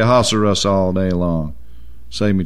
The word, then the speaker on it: Ahasuerus: Now it came to pass in the days of Ahasuerus. That's how you say his name.